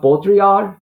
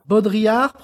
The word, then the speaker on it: Baudrillard: And this concept, hyperreality, the term itself was coined by a sociologist, a French sociologist named Jean Baudrillard. I'm not sure if my pronunciation is correct. Jean Baudrillard. Baudrillard? – Baudrillard, probably. We probably. – Baudrillard. Yeah. – Usually don't pronounce the D.